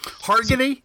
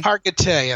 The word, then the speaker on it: Hargitay: Hargitay? That's how you say it? Hargitay? Hargitay? – Hargitay, I think it is. Mariska Hargitay. Yeah, because Mickey Hargitay, and that's a Hungarian name.